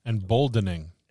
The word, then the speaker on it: Emboldening: Embolding. Am I saying that right? Embolding body or bond. – Embolding bond. – Embolding bond. – Emboldening. – Emboldening. Yeah, that's what I thought. I thought it was another syllabic in there, syllable in there.